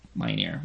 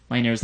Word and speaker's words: Minear: Um, now, Joss Whedon and Tim Minear, I think that's how you say his name because I think I've heard them say his name Minear, which is weird to say.